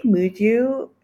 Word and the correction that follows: Muji: is that how you say it? Muju? Muji? Muji? – Muji? Muji? – Muji? Yeah, Muji has them.